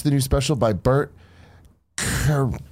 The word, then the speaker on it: Kreischer: Bert Kreischer.